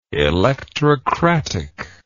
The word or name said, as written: electrocratic